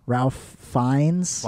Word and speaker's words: Ralph Fiennes: Ralph Fiennes? – Ralph Fiennes, you mean? – Is that how you say it? – Yeah, he also played Voldemort in the Harry Potter movies.